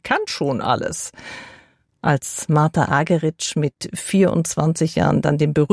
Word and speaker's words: Argerich: Zum Orchester mit Charles Dutoit kommt jetzt Martha Argerich als Solistin dazu. Hierzulande wird sie ja oft eingedeutscht Argerich genannt, aber sie selbst spricht sich Argerich.